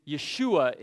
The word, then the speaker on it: Yeshua: So when you go to, we're reading in Greek, which when we look at the Greek letters and then we pronounce them into English, it sounds like Jesus, and that's why we say it that way. But the word is Yeshua in Hebrew.